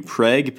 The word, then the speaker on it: Prague: I don't know how the pronunciation was changed to Preg, but it's a kind of a common affect that American style English has.